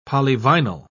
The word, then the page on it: polyvinyl: British English